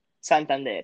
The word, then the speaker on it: Santander: Santander, yeah.